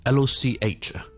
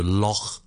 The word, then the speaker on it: loch: OK，而呢个音咧，我哋喺有啲字都会见到嘅，譬如我哋苏格兰咧，我哋讲一啲湖咧，L O C H 我哋叫做 Loch Loch Loch，都系有呢、這个呢、這个音嘅。 OK，而呢个音发音嘅位置其实咧，同我哋 K 音嘅位置好近嘅。